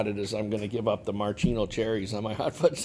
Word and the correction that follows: maraschino: Marcino, or how do you say that? – Maraschino.